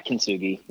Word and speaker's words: Kintsugi: I'd love to hear when in relation to the Nest this was, but you went back and you did Kintsugi. – Yeah. – Am I pronouncing that correctly? – Yeah, Kintsugi. Yeah.